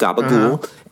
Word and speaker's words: gabagool: But I just looked it up, Gabagool, and they said it's how Southern Italians pronounce capicola.